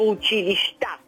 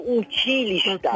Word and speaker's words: училища: Ами ето, неправилно е да се казва училища. Казва се училища. – Е. – Неправилно поставяне на ударението.